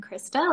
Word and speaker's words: Christa: I butchered it so badly, but it's okay. – No, that's totally fine. You did great. I am Krista, like you said,